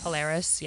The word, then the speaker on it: Polaris: And it was performed at, and excuse me if I pronounce this wrong, but Polaris Amphitheater in Columbus, Ohio. – I guess Polaris. – Polaris, yeah.